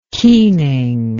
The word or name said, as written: keening